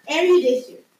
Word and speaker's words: erudition: It's called erudition.